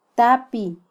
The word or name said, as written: tapi